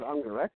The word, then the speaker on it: Ungerecht: We usually pronounce it Ungerecht, but I like the way you pronounce it.